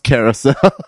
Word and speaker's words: Karisselle: Spelled something like K-A-R-I-S-S-E-L-L-E. – That's what you have in the notes. – Yeah, but it's pronounced Carousel.